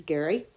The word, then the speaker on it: Gerry: Anyway, do please go to All Things Cruise, because there you can read what Gary has written, G-E-R-R-Y, pronounced Gary.